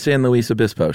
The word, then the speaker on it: San Luis Obispo: San Luis Obispo, sure.